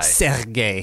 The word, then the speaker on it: Sergei: Sergei. You got to roll that R.